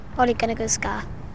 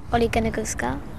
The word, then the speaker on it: Ole Gunnar Solskjær: The biggest question of the lot, though, is how exactly do you pronounce the new manager's name? – Ole Gunnar Solskjær. – Say again. – Ole Gunnar Solskjær.